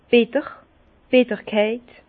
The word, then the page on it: bitter, d' Bitterkeit: Bas-Rhin; Herrlisheim